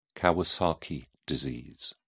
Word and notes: kawasaki disease: British English

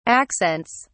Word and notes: accents: British English

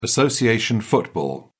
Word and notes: association football: British English

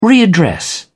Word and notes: readdress: British English

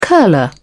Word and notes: curler: British English

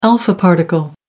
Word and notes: alpha particle: American English

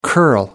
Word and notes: curl: American English

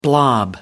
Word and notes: blob: American English

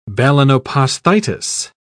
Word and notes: balanoposthitis: British English

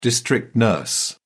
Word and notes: district nurse: British English

